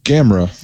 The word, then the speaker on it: Gamera: Uh this week in Kai July, we are discussing uh now what's the correct correct pronunciation of this creature's name? – Gamra.